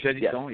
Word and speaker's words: Janisson: Janisson, am I pronouncing that right? – Yeah, Janisson, yes.